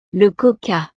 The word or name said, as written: le coca